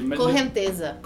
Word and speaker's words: correnteza: It, what how, cohenche. – cohenche. – Cohenche. – Correnteza. – Correnteza. – Correnteza. Yes. – Uh, that is uh, how you pronounce uh, undertow which is the the uh, flow of the wave.